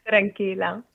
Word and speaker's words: tranquila: And then Alexia says she's so at peace and so tranquila. Is that how you say it? Tranquila? – Yeah, tranquila.